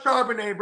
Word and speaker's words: Charbonnet: They come through, oh, I'm telling you, bro, Charbel Blah, bro. Fucking Walker. Charbel Blah is going to be the guy. Bro, it's pronounced Charbonnet, bro.